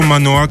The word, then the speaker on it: Manoa: Donc à suivre sur tous les réseaux sociaux, Manoa, comme ça se prononce, M-A-N-O-A.